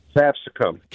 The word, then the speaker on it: capsicum: How do you say it? – Capsicum. – Capsicum. That's what I thought.